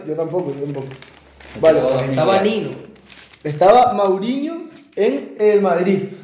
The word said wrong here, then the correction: Mourinho: Mauriño. – Que tiró 2014. – Mourinho. – Mourinho, Mourinho. – Mourinho, perdón, perdón.